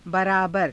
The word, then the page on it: baraabara: hindi